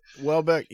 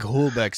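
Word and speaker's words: Houellebecq: Welbeck, yeah, he said, I guess it's how you pronounce. No, it's I was mispronouncing it for 10 years, and then Anna on Red Scare started pronouncing it correctly for me. So kudos to her. I think Holdebeck sounds better, but we'll just say Welbeck.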